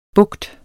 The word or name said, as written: bugt